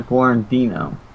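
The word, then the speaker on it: Guarnido: Is that Wano? I can't pronounce his last name. Gardino? Sucks not being able to read, so. Best Guarandino.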